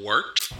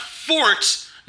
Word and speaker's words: wort: It is the wort, not fort, not short, the wort.